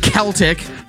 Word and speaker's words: Celtic: By the way, it's pronounced Celtic. – Whatever. – I know. You can get that because they're called the Boston Celtics, even though it should be called the Celtics.